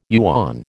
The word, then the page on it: yuan: American English